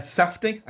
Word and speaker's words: Safdie: Uncut Gems um, is a Josh and Benny uh, Safdie. I am saying that right, aren't I? Mm-hmm. Good.